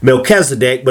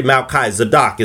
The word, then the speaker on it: Melchizedek: Some people pronounce it Melchizedek, but it's really Melchizedek. It's two words, Malchi and Zadok.